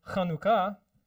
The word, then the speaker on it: Chanukah: Same thing with Chanukah becomes Chanukah. Okay, and you got to say the ch.